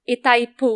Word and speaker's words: Itaipu: One of the biggest hydro plants hasn't been privatized, which is Itaipu. It's one of the biggest in the world. – Sorry, how do you pronounce that? That was Itaipu. – I- Itai. – Itaipu. – Itaipu. – Okay.